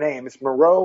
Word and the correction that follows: Reneau: or yeah, Renee. Marion Reneau.